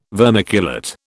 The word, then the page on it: vermiculate: British English